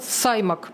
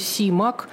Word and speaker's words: Симак: 120 лет назад на свет появился американский писатель-фантаст Клиффорд Саймак. мы вот его именно под этим именем знаем, но оказывается, правильно произносить его фамилию Симак, о чем отдельно писал Айзек Азимов.